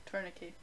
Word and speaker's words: tourniquet: Is that how you say it? – Tourniquet. – Yeah, that's the one.